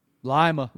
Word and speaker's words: Lima: No, I grew up in Lima, Ohio. – Lima. – Spelled like Lima. Yeah. – I would have said Lima.